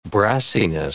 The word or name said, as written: brassiness